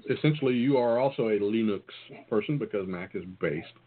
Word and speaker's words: Linux: Rhymes with Spinux. Linux, baby.